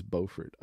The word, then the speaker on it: Beaufort: People say Beaufort, but not Beaufort. It's Beaufort.